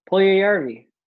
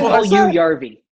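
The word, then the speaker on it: Puljujärvi: How do you pronounce it? – Puljujarvi. That's what I said. No, you said Yarvi.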